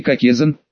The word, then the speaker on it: кокезан: Требуется произнести кокезан, ударение на второй слог.